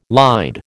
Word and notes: lied: American English

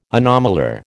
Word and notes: anomalure: American English

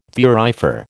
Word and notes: thurifer: American English

